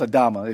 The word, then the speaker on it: Adama: And, or it would look like Adama, but I believe it's pronounced Adama if you look behind the word ground.